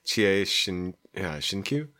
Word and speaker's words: Chie Shinkyu: All right, so if you don't know Wakaku Zake, which is by, and I'm gonna mispronounce this horribly, Chie Shinkyu.